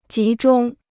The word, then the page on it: 集中: jí zhōng